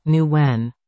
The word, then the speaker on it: Nguyen: Portland Winterhawks right winger Marcus Nguyen pronounces his last name, Nguyen.